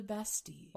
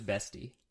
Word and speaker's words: Bastille: So sit down, buckle up, and get ready to talk about the storming of the Bastille. – Also, it's Bastille? I thought it was Bestie.